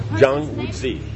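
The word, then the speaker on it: Zhang Wuji (张无忌): Jiang Wuji, that would be how you say it? Jiang Wuji, would that be a name? Yeah, is that a proper? Huh? – Zhang? – Zhang Wuji, yeah, okay, it's a person's name.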